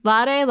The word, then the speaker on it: laude: Laude, laude. I forget how to pronounce that. – Laude.